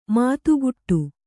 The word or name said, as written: ಮತಿಗಿಡು